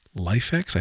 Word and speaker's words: LIFX: I use the Halloween themes from LifeX. I think that's how they pronounce their company name, LIFX.